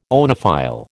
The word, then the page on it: oenophile: American English